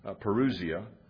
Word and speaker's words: parousia: Some use, say, parousia, but I actually pronounce it parousia.